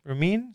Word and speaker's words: Romijn: And that's Rebecca Romijn? Romijn? Is yes. – How we say her name? – Correct.